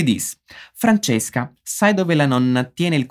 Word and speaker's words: nonna: Você percebeu que a palavra para vovó é nonna, assim mesmo, com uma doppia N. Ripeti com'è: nonna. Nonna. O cuidado com a pronúncia das duplas consoantes nessa e um bom número de palavras garante que você não diga uma palavra diferente, que é o caso de nona, que tem um N só nesse caso, que vai significar nona, isso mesmo, o número ordinal, oitava, nona, décima e assim por diante.